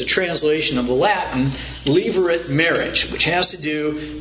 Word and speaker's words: Levirate: Levirate law. That is spelled like Levi, L-E-V-I, and then rate, R-A-T-E. One word, pronounced Levirate, the Levirate law. That law exists, is named out of the Latin term levir, L-E-V-I-R, which means husband's brother.